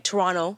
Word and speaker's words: Toronto: I was getting Toronto. You don't pronounce the T, by the way, if you want to sound like you belong.